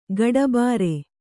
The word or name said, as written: ಕಟ್ಟುಬ್ಬರ